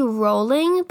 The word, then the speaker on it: Rowling: Her name is actually pronounced J.K. Rowling, but she doesn't care that people pronounce her last name wrong anymore, so she's J.K. Rowling to everybody.